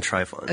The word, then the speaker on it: Trifon: T-R-I-F-O-N. – I was saying Trifon.